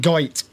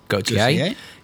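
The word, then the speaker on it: Gaultier: So, yes, it's pronounced Gautier, as in Jean-Paul. – I always called it Goit.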